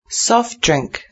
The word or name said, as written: soft drink